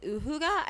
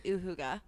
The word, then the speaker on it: uhygge: If you haven't listened to, I believe it's season 4, episode 28, our uhygge, or is it uhygge? I think it's pronounced uhygge.